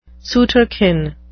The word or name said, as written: sooterkin